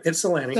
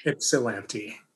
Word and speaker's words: Ypsilanti: Yep, Ypsilanti. – So like IP, not Ypsilanti. – No, it's Ypsilanti. – But it's pronounced Ips. But it's pronounced Ipsy. Ypsilanti.